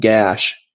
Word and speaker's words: GACH: And I pronounce it gash because it just gashes my budget and it's all stuff I hate buying.